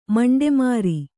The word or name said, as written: ಮಂಡೆಮಾರಿ